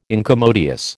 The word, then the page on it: incommodious: American English